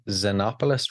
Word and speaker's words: Xenopoulos: I hope I don't butcher this name, but it was produced by Kris Xenopoulos from Vulvodynia.